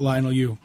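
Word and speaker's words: Leinil Yu: But possibly the definitive Superman story, at least in my opinion, the Mark Waid, Francis Lennell Yu, or what am I mispronouncing that last name? Of course I am. – That's what we do. I think it's Francis right. Lionel Yu. – Francis Lionel Yu, the Birthright 12-part maxi series that retells Superman's origin, and I think this is a great place for people to start.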